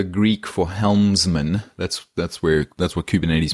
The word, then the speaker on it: Kubernetes: Yeah, Kubernetes. Kubernetes.